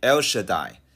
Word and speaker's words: El Shaddai: 全能的神在原来的文字中读音接近于 El Shaddai，伊勒沙代。